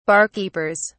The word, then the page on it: barkeepers: British English